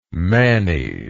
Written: manny

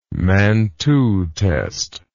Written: Mantoux test

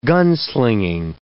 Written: gunslinging

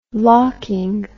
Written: locking

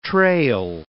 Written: trail

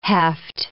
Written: heft

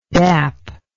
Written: dapping

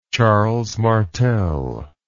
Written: Charles Martel

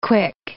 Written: KWIC

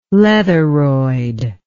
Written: leatheroid

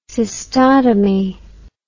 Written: cystotomy

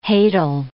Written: hadal

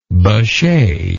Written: bechet

